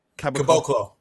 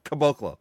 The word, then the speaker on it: Caboclo: Cab- Cab- Caboclo. – Caboclo.